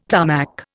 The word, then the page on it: Tarmac: Indian